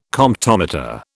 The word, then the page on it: Comptometer: British English